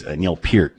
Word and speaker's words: Neil Peart: Eric Thomas always gets mad when we pronounce Neil Peart's name wrong, so it's Neil Peart, in case Eric's listening right now.